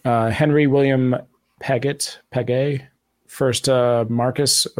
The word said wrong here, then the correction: Marquess: Oh, I'm pronouncing everything wrong. This is why I didn't want to do this. Sorry, I'll fix it. One famous ghost is Henry William Paget, first Marquess of Anglesey.